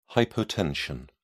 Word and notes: hypotension: British English

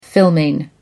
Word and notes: filming: American English

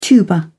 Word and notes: tuba: British English

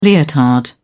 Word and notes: leotard: British English

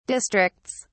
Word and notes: districts: British English

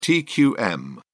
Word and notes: tqm: British English

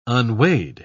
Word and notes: unweighed: British English

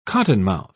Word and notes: cottonmouth: British English

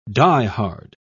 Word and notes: die-hard: British English

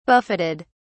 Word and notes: buffeted: British English

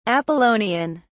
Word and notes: apollonian: British English

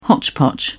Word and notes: hotchpotch: British English

